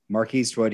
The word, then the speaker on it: MRKSTWD: That is spelled MRKSTWD. Mark Eastwood, he's an illustrator in Melbourne, Australia.